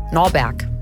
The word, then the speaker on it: Norberg: Er nimmt ihren Namen Norbert. Norberg. Norbert. Norberg. – Du kannst das nicht immer falsch aussprechen.